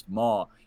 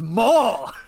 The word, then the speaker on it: maw: Dwight's Christmas, he's eating something, and he's like, "This hog mama is great." And they go, "I believe it's pronounced maw." He spits it out. "What is maw?"